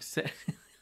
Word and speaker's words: Seyfried: Because you could have made a movie about, yeah, this Seyfried character, keep saying her name wrong. – Seyfried. – I keep thinking Siegfried and Roy. Seyfried.